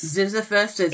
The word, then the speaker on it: Ziziphus: Yeah, and in fact, there's one plant, and I'm going to say it wrong because I always say it wrong, but I think it's Ziziphus. – Ziziphus.